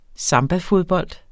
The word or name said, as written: sambafodbold